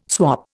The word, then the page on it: swap: Indian